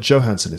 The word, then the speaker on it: Johansson: Scarlett Johansson is actually how you pronounce it.